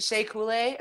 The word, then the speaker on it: Shea Coulee: You know who, is it Shea Coulee? Is that how you say her name? – Yeah, absolutely.